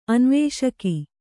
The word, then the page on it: ಅನ್ವೇಷಕಿ: anvēṣaki